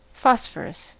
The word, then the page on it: phosphorus: American English